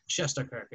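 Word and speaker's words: Shesterkin: I don't think he was supposed to even start the first game, or I think he started the first two before as a Scheidenkirk. I'm sorry, I'm butchering his name. I apologize for that. Shesterkirk, excuse me.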